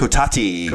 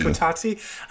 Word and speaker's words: Kotati: Was it the Coterie? Coterie? How do you say that? – Kotati. – Kotati.